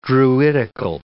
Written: druidical

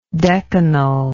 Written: decanal

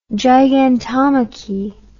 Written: gigantomachy

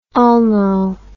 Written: ulno

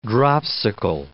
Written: dropsical

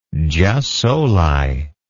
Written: jus soli

